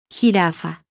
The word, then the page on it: g: letter; Spanish alphabet